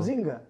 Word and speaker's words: Zynga: Haftanın önemli haberlerinden biri Türk oyun geliştiricisi e, olan Gram Games'in bir başka dünya devi e, oyun geliştiricisi olan e, Zynga diye okunuyor değil mi bu? – Zynga.